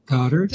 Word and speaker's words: Godart: G-O-D. – Yeah. And let me spell that, because often people misspell that. It's G-O-D for God and D-A-R-T for Dart, Goddard.